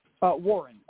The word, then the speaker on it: Warren: My name is Warren. I heard about you on Mike Adams. – I did not hear your first name. Say it again, please. – Warren. – Spell that, please. – W-A-R-R-E-N.